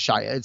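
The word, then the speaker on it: Shysa: Fen Shaya is how you say, I think it's Shaya. It's S-H-Y-S-A.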